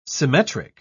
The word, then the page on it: symmetric: British English